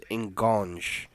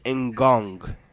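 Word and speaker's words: Ngonge: I just want to make sure I was doing it because the way I pronounce it was Ngong. – That's what I was doing. – But it's actually Ngong.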